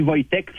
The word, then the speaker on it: Wojtek: Chez nous, on dit Wojtek, qui s'écrit W-O-J-T-E-K, comme le W se prononce par V, mais chez nous, c'est Wojciech.